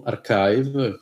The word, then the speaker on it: arXiv: E poi sì, se volete contattarmi, scrivetemi, basta che cercate il mio nome su Archive, che si scrive A-R-X-I-V.